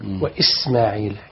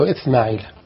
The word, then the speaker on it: إسماعيل: بس السين نخلي فرق بين السين والثاء. وإسماعيل إس فصفير كده. بعض ينطقها وإثماعيل، البعض ينطقها كده.